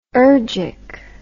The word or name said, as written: ergic